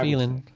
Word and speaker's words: Phelan: P H E L A N, feeling. Am I pronouncing it correctly? – Eh, I think I got a feeling that you are. Yeah. – Oh, I got a feeling.